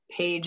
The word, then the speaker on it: Paige: Paige is spelled P-A-I-G-E, not like the page in a book.